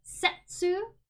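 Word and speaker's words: setsu (切): It means cut, and it is pronounced setsu.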